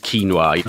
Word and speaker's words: quinoa: No, it should be pronounced quinoa, though a lot of people make that mistake.